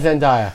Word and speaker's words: Zendaya: That's Zendaya. – Zendaya.